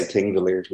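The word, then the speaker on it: Þingvellir: The other day, Thingvallen, is that how do you pronounce it? – I'll try my best. So most people say Thingvellir, like Thingvellir.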